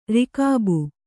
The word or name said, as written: ರಕಾಬು